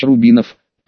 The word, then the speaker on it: Рубинов: Есть в Знаменске один старый бизнесмен, очень серьезный, для большинства людей Михаил Львович Рубинов. Произнесла она фамилию с ударением на последний слог, для остальных же просто Рубин.